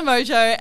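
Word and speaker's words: Mongeau: We're going to be talking about Tana Mojo and, am I saying her name right? – Tana Mongwao, Meng Mongyu. I don't think it's that one. I'm pretty sure it's Mojo. – Tana Mojo and her new influencer agency.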